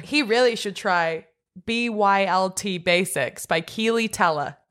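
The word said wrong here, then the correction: Keleigh: Kelly. Her name is pronounced Kelly.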